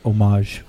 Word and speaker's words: homage: And so I like that, you know, in paying homage to George Takei. – It's pronounced homage. – I'm not French.